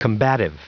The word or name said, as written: combative